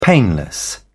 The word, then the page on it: painless: British English